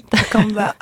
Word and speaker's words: Takamba: Dans un autre titre, Takamba, je le prononce bien ? – Oui, Takamba.